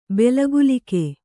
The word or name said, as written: ಬೆಲಗುಲಿಕೆ